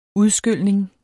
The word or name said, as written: udskylning